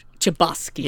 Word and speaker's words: Chbosky: Steven Chbosky. – Chbosky.